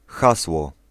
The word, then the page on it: hasło: [ˈxaswɔ]